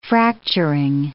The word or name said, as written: fracturing